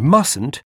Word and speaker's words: must: We don't pronounce the final T in must and mustn't before a consonant.